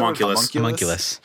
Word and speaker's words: Homunculus: From there, it's a statue of a called like Roger the Homuncle. Is it? How do you pronounce it? – Homunculus.